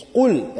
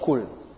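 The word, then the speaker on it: qul (قل): It's not kaf, is qaf. So it's qul and not kul.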